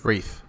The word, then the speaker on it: wreath: Wreath or reef? – Wreath. – Yeah. – With the TH. – Yeah. – With a TH.